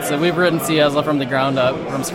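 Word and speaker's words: CyaSSL: We're at CYASL, is that right? – We pronounce it CYASL.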